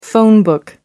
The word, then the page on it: phone book: British English